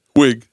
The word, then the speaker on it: Wiig: Wiig. Yep. Yeah. You said it right.